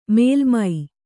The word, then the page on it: ಮೇಲ್ಮೈ: kannada